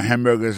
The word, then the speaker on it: hamburger: N'a pas remarqué que dans mot hamburger ça comme t'as dit, il y a trois syllabes là-dedans, côté appuyé puis plus ça c'est sous premier syllabe là.